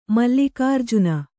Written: ಮಲ್ಲಿಕಾರ್ಜುನ

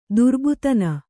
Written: ದುರ್ಬೋಧನೆ

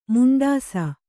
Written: ಮುಂಡಿಸು

